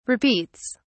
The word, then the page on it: repeats: British English